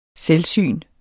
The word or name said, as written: selvsyn